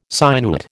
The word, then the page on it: sinuate: American English